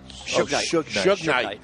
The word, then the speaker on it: Suge Knight: Also known as Sug Knight. – Suge Knight.